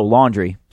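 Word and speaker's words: Laundrie: Brian Landry. No, Laundry. Sorry.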